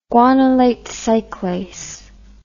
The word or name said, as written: guanylate cyclase